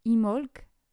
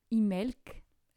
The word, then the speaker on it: Imbolc: A priori, on prononce Imolc ou Imelc. Euh, par, les, Irlandais.